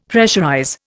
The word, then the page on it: pressurize: Indian